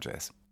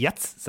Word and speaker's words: Jazz: Ja, und wenn du die vier Buchstaben sagst, dann muss ich gleich daran denken, dass es Leute gibt, die es anders aussprechen. Du bist jung genug dafür. Ich finde es noch lustig, dass es immer noch Leute gibt, die Jatz sagen.